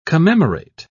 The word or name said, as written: commemorate